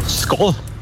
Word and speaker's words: Skål: Skål. – Wow. Richtig? Echt? Ja. – Oh. – Du hast sogar richtig betont.